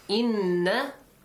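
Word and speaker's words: إنّ: Inna, make sure you pronounce it with a nasal sound. And extend it for two counts.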